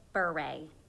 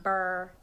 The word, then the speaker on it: Bure: And yes, my last name is pronounced Bure. – Holy. – Not Burr.